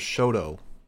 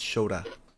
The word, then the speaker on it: Shota: Which they kept pronouncing his name as Shoto. It's Shota. I think Matt Raywalt was just calling him Shoto. Kevin Kelly was pronouncing it right.